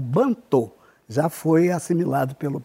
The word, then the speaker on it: Bantu: Bantô com O no final, e Bantu, que as pessoas naturalmente pronunciam como Bantu.